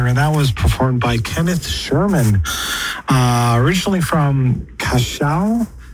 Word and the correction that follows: Curaçao: Is that the right pronunciation? The Cachao in, Curaçao in the Caribbean,